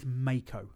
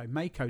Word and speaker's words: Meiko: And she confirmed it is Meiko. – Yeah. – I wrote it down. I didn't say why I wanted to know how do you say this or anything. – Yeah. – I said, how do you say this name? And she said, Meiko. So Meiko Satomura.